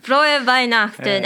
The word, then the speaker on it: Frohe Weihnachten: There we go. That's the proper way to say it. Frohe Weihnachten.